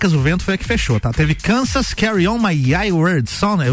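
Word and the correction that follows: Carry On Wayward Son: Carry On My Wayward Sun, é isso o nome da música.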